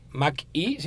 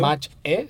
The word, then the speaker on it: Mach-E: Mach-E, si lo pronunciamos en inglés. – Correcto. – Mach-E, si lo pronunciamos en español, que será una SUV totalmente eléctrica, inspirada nada más y nada menos que en el Mustang.